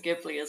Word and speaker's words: Ghibli: All right, by the way, is it Ghibli or Ghibli? – So I think in Japan they actually pronounce it Ghibli, but they said, I remember them saying it's okay to pronounce it Ghibli as well.